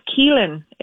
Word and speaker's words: Caelainn: My real name is Caelainn. It's an Irish name, um, which is often mispronounced even in Ireland.